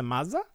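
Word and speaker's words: Maza: and Lisa Mazza. Is that how you say her name? – Yes.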